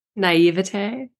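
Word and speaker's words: naïveté: Naivete. – Naivete. Oh, that doesn't sound right either, but okay. – We'll say naivety. It's not naivety. – Naivete.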